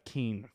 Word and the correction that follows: King: Did I say that right? – No. – Fuck. – Do you ever? – No. – Okay. – King. How about that time? Better? – You're good.